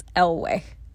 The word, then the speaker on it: Eyllwe: We looked up the pronunciation and it's Eyllwe.